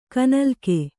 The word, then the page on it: ಕೈನಿಲುಕು: kainiluku